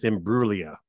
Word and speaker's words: Imbruglia: There's no G pronounced. It's just Imbruglia, apparently.